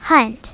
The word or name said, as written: hunt